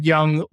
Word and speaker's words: Yeung: Or of course, you can just look at my name, Nathan Yeung, spelled with a Y-E-U-N-G on LinkedIn. I don't think there's a ton of Nathan Youngs that are marketing, so should probably pop up there.